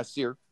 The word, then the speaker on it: Cyr: C-Y-R. Is that how you pronounce that? – Yeah, Seer.